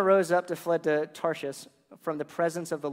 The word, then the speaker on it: Tarshish: That's one of those words that you're like, how do you say that one? I actually Googled it. It's Tarshish.